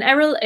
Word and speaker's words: Eriol: And Eriol is spelled E-R-I-O-L.